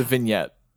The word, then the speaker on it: vignette: No energy to start, and yeah, just it was like the first vignette. – You don't pronounce the G typically. – The first scenario. – It's a vignette. – Vignette. – Stop saying the G.